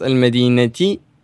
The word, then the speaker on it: المدينة: And as for the last word in this sentence, which is المدينة, is actually pronounced المدينة with a light E sound at the end because it has been afflicted with جر due to the إلى that was before it, and so it is in the genitive case.